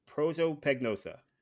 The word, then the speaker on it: prosopagnosia: But so you have prosopagnosia.